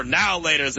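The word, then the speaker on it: Now and Later: So I was just doing the two different pronunciations. I say now and laters. You say now laters like it's all one word.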